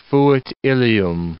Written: fuit Ilium

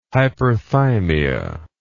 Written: hyperthymia

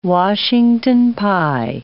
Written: Washington pie

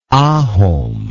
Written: ahom

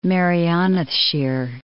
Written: Merionethshire

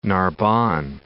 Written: Narbonne

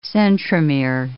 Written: centromere

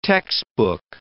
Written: textbook

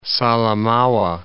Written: Salamaua

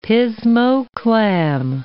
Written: pismo clam

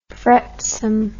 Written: fretsome

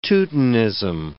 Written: Teutonism

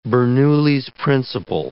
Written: Bernoulli's principle

